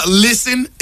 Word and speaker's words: listen: Listen is another one where the English words has a silent letter. – Silent and listen. – T. Listen. You don't hit no T in listen.